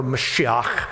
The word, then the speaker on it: Mashiach: They spoke Hebrew or Aramaic, and in Hebrew, they would have said Messiah, or Mashiach is the way that's actually pronounced.